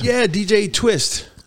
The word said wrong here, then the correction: tWitch: DJ Twitch.